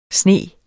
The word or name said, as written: SNE